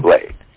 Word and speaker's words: Candomblé: Candomblé.